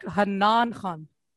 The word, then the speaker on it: Hanan Khan: It's more like Hanan Khan.